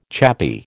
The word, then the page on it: chappie: American English